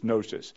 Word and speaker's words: gnosis: There are many different traditions for pronouncing both Greek and Latin, and I'm going to use the English pronunciation of them, the traditional English pronunciation, which in this case is gnosis.